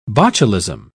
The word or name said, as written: botulism